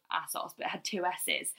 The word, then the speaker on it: ASOS: Is it ASOS, right? – I'm ASOS. But some people say ASOS.